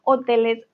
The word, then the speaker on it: hotel: En este caso, hotel se pronuncia de todas maneras con O, entonces no hay problema, ¿vale?